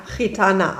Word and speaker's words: Gitana: Rumba Gitarra. – Naja, Gitana, aber ist nicht so schlimm.